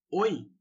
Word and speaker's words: hoy: E uma outra coisa interessante é que lá é, não se pronuncia o H, tá? Então, por exemplo, oi. Oi, na verdade, se escreve H-O-Y. Oi.